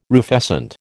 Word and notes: rufescent: American English

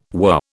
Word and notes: were: British English

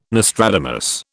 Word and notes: Nostradamus: British English

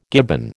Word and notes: gibbon: American English

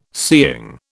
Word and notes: seeing: British English

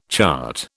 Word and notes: chart: British English

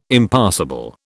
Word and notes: Impassable: British English

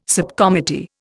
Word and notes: subcommittee: Indian

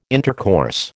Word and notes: intercourse: American English

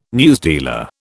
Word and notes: newsdealer: British English

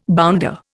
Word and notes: bounder: Indian

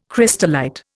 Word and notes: crystallite: Indian

